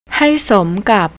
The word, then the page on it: haisomkap: thai